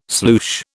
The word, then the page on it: sloosh: British English